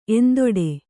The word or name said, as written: ಎಂತುಟು